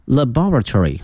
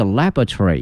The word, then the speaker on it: laboratory: The OSCAR 哈，那么它有一个缩写啊，叫 OSCAR Building will provide 20,000 square meters， 20,000平米哈，这个平米叫 square meter， a high quality 高品质的 research laboratory 哈。那么实验室呢，有两个发音，一个是 laboratory，一个是 laboratory，都可以哈。